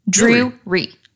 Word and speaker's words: Drury: Drury. Drury. – Drury. It's like Drew and Re. – Drew Ree.